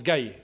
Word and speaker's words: Gaï: Alors, la quatrième vallée, c'est Gaï, c'est pas gay, hein, excusez-moi, mais Gaï. Ça se prononce Gaï, ça n'a rien à voir avec les gays.